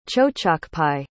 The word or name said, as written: chochokpi